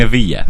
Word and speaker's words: Βίλια: Δεν είναι Βίλη, είναι Βίλια. – Βίλια. – Σεβίλια γράφεται. – Ναι. – Απλά προφέρεται γεια γιατί είναι double L στην Ισπανία.